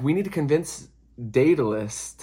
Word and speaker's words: Daedalus: So they're like, we need to find, I think you say his name, Daedalus? Hold on. It's like D-A-E-D-A-L-U-S. Daedalus.